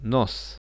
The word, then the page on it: nos: Russian